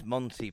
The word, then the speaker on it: Mante: And he spells Monty, M-A-N-T-E. But it is Monty pronunciation.